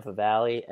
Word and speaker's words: Favale: If you want to talk to me, I am at Dan Favale, FAVALE.